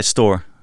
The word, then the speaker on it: Stoor: So I wanted to kick off by talking about the Stoor project. Is that is that the right pronunciation actually? Just just to establish that. Uh, yeah, Stoor.